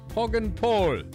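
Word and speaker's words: Poggenpohl: and I know how to pronounce this, Poggenpol, because it's German.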